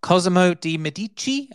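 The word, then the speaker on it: Cosimo de' Medici: Cosimo Di Medici, I believe that is the correct pronunciation of a,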